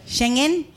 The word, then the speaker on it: Schengen: Like, so one, the first one was like the Shenzhen visa. How do you say it? – Schengen. – I've been saying it wrong.